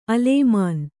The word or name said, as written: ಅಲೇಮಾನಿ